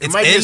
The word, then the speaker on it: Nwigwe: Yeah, Nwigwe. – Isn't there a silent O in there? – Nah, it's Nwigwe.